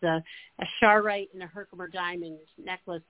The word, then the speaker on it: Charoite: So the crystal, if you have a pen, it's spelled C-H-A-R-O-I-T-E. Okay? – I believe, okay. Mary, it's, they pronounce it Charite.